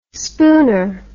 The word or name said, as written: spooner